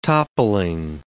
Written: toppling

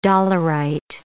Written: dolerite

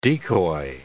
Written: decoy

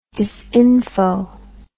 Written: disinfo

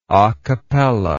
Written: acappella

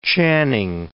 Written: Channing

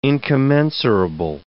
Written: incommensurable

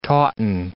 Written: tauten

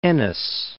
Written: Ennis

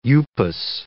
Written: upas

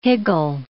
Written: higgle

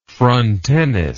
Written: frontenis

